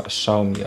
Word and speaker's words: Xiaomi: W zasadzie tutaj jest kwestia pisowni chyba najważniejsza, pisane przez X, Xiaomi. Czytałem, że wymawia się właśnie Xiaomi, ale nie będę wnikał w chiński język, nie znam się na nim.